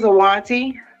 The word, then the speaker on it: Iuwanti: My name is Awanti. – Awanti? How do you spell that? – I-U-W-A-N-T-I. – I-U-W-A-N-T-Y.